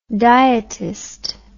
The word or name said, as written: dietist